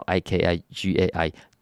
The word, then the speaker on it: Ikigai: 英文直接叫 I K I G A I，念作 Ikigai。